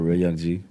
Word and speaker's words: RealYungG: At RealYungG. – RealYungG. No underscores. R-E-A, because some people put O in my name.